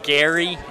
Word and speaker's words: Geary: It's pronounced Gary, as if it was G-A-R-Y. – Okay. – That's how it's pronounced, but I hear it so many different ways that I will answer literally to anything. – Fair enough. I always thought it was Geary. Now it's Gary.